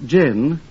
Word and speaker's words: John: While his middle name is spelled J-O-H-N, John, it's actually pronounced gin, as in a martini.